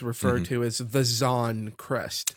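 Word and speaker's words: Xan: So her full name is Zan Irelia. And that's X-A-N, Zan Irelia. That is the pronunciation that we are sticking to.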